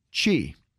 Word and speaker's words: Qi: Now, QI is pronounced chi. Make certain it's pronounced chi certified, and that's the new standard for wireless energy transmission.